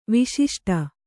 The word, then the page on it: ವಿಶಿಷ್ಟ: Kannada; viśiṣṭa